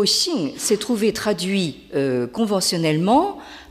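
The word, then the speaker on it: xing (性): Alors, donc, toute la discussion tourne autour d'un mot qui s'écrit et se prononce en chinois moderne, vous l'avez en haut de la diapositive à gauche, donc « xing ».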